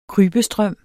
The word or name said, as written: krybestrøm